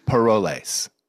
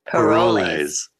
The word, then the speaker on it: Parolles: I don't know if that's helpful for everyone, but they normally say that ES on Parolles.